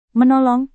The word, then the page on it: menolong: Indonesian